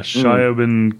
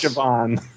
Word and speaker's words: Siobhan: Yeah, Shyobin Quinn. – That's Siobhan. That's pronounced Siobhan.